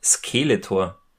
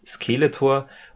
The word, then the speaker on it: Skeletor: Francis, der sagt nämlich immer Skeletor. Also er betont das erste E so, er betont die erste Silbe, das erste E so ein bisschen länger, so ganz klassische deutsche Betonung.